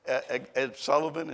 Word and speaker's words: Ed Sullivan: Ed Sullivan, is that? Yeah, that's how you say it.